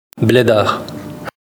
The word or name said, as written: bleddar